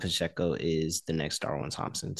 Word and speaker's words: Pacheco: Pacheco. – Pacheco, yeah.